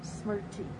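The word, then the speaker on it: Smriti (स्मृति): Now, the Sanskrit word for mindfulness, and please help me pronounce it, is Smriti. – Smriti.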